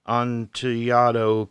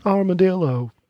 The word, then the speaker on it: Amontillado: The cask of Amontillado. Amontillado. Two L's is supposed to be a Y. – Only in Mexico. – It says a collection of American whiskey finished with Amontillado cask staves.